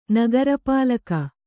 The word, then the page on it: ನಗರಪಾಲಿಕೆ: nagara pālike